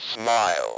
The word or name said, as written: smile